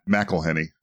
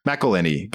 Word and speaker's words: McElhenney: I guess it'd be McElhenney, right? Or how do you say it? I never remember. – McElhenney. – McElhenney.